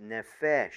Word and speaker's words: nephesh: It is pronounced nephesh, N-E-P-H-E-S-H.